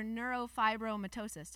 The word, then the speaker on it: neurofibromatosis: This is the walk for neurofibromatosis.